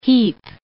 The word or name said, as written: heap